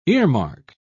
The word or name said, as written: earmark